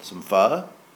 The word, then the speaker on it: pho: I reached out to my older son, asked him if he wanted to go get some pho, which for the uninitiated or unaware, that's spelled P-H-O.